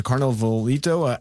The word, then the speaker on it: Carnavalito: And at Surrey's Bear Creek Park, Carnavalito. So Carnavalito, I'm mispronouncing it, I apologize. – No, you don't. It's beautiful. Carnavalito.